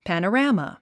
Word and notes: panorama: pæ`nərǽmə; パノラァマ

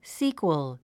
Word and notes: sequel: síːkwəl; スィークワル

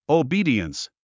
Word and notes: obedience: oubíːdiəns; オウビーディエンス

